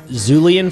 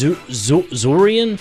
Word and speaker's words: julienne: Yeah, I looked up the pronunciation, and it says Z-H-R-O-O-L-E-E-E-N is the pronunciation. But it's spelled out like J-U-L-I-E-N-N-E. – Julian fries? I don't know. – It says Zurian fries?